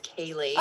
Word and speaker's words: Kailee: So it's funny because a lot of people pronounce my name Kylie, but it's Kailee. – Oh, I'm sorry. The oh, should oh, have no, asked at the beginning. – The K A I throws everyone off, but it's okay.